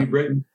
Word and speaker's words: New Britain: New Britain.